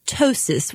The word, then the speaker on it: ptosis: In combination with that, ptosis, which is spelled with a silent P, P-T-O-S-I-S, and that's when the eyelid edge itself droops down.